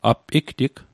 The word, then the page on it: app igtig: app iktik